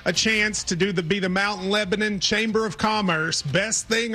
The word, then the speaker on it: Mount Lebanon: How do I say the town you're from? – Mount Lebanon. – Lebanon, okay. Like we say it in Kentucky, Mount Lebanon.